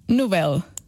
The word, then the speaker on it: Nobel: Nós quando pronunciamos, por exemplo, papel e anel, nós pomos a sílaba tónica na, na segunda sílaba. Nobel. Nobel, Noel. E Nobel sim. – É exatamente a mesma coisa.